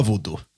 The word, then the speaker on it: voodoo: Uttalas man så? Voodoo. – Ja, voodoo. – Voodoo, ja. Afrikansk skrock.